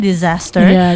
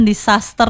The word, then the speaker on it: disaster: Ya, jadi kalau misalnya bencana aja guys itu namanya disaster. Dan bacanya tuh bukan disaster. Ada Z-nya dikit ya.